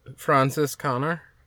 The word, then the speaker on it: Franziskaner: It's Francis Connor. Am I saying that right? F-R-A-N-Z-I-S-K-A-N-E-R. – Francis Connor? – Yeah. Yeah, that's close enough.